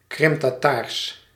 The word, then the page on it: Krim-Tataars: Dutch